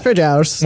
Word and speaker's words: Trejares: Treasurers. – Well, it's a take on Treasurers, but it's spelled T-R-E-J-A-R-E-S.